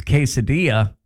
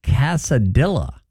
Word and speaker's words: quesadilla: Another word is quesadilla. My mom says Casadilla, she said, and it drives her crazy. Casadilla. – I can understand that. It's kind of the way it's spelled.